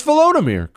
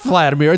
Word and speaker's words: Volodymyr: It's Volodymyr, Chris. It's not Vladimir, it's Volodymyr.